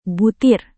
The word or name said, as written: butir